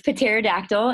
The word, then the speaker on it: pterodactyl: We pronounce the P in pterodactyl for the record. So it's pterodactyl, and she's a special needs kitten as well as rowdy cat who we go to surgery with.